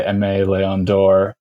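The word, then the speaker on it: Aimé Leon Dore: So I wrote up the there's going to be different pronunciations of this brand. I'm going to say M.A. Leon d'Or.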